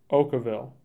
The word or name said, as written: Okawville